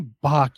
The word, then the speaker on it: Bach: You know, she is very pretentious, You know, she does that obnoxiously throaty pronunciation of Bach, where it's like Bach, Bach. It's like, just say Bach, you fucking.